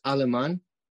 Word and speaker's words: Aleman: Is it Alman? Is it correct pronunciation last name? – Yeah, uh, it's Aleman. Aleman, gotcha.